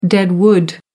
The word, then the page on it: dead wood: American English